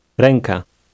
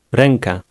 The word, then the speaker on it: Ręka: Pay attention to the sound of the second letter in the word. – Ręka. Ręka.